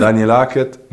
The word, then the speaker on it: Daniel Hackett: Ich spiele das nochmal ab. Das ist vom Euroleague, offizielle Euroleague Pronunciation Guide. – Nein. Daniel Hackett, Number Zero. – Das ist er selber. – Das ist er selber, ja, genau.